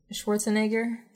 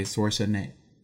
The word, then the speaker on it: Schwarzenegger: Schwarzenegger. – Okay, Schwarzenegger. Focus on the A, Schwarzenegger. – Like Jaeger, but...